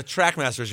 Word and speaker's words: Trackmasters: The fucking Trashmasters. – No, Trackmasters. You're mispronouncing it.